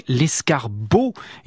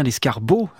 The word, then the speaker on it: escarbot: Et je lisais tout à l'heure ce poème d'Aloysius Bertrand avec l'escarbot, et non pas l'escargot, c'est bien l'escarbot que j'ai prononcé.